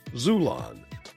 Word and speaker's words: Xulon: Pick up your copy today at Amazon, Barnes & Noble, or my publisher, Zulon. That's spelled X-U-L-O-N.